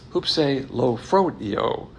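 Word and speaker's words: hupselophroneo: It's H-U-P-S-E-L-O. P-H-R-O-N-E-O, and it's pronounced hoopse lo phone fronio. Hoopse lo fronio.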